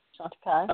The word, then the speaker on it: Chantecaille: And correct me if I'm saying this wrong, but the Chantecaille, is it Chantecaille? – Yeah, Chantecaille.